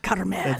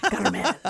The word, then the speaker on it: Carmel: Do they hard breathe the end of the word like that? Carmel. – Yeah. Carmel. – They all say it like that. – Kinda. Yeah. – Oh, seriously. Okay. – Yeah. Kinda. Carmel. You got to roll the R a little bit, but oh, that's cool.